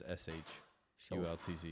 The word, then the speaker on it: Shultz: It's just S H U L T Z.